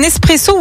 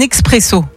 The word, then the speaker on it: espresso: Alors, tiens, est-ce que tu dis un espresso ou un expresso? – Pour le café? – Oui. Ouais. – Espresso. – Espresso, ouais. Étymologiquement, on ne prononce pas le X.